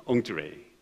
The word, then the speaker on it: Entrée: 저게 이제 불란서인데 제가 발음을 해보겠습니다. Entrée. 이게 되게 어려워요. 어제 그래서 제가 결혼식장에서 발음 교정을 받았는데 맞는지 모르겠습니다. Entrée. 예. 불어를 잘하시는 분들은 거슬리겠지만, 예. 그러나 제가 확신을 얻은 것은 일부 예배 때 끝나고 불문과 출신이 저한테 얘기했습니다. 목사님 발음 좋습니다.